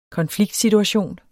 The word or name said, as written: konfliktsituation